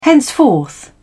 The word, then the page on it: henceforth: British English